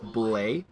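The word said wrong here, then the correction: Bulleit: Bullet. – Yeah. Okay. It's the French part of me wants to say boulet, but I wasn't trying to pronounce that.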